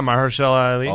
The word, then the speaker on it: Mahershala Ali: Yeah, Mahershala Ali.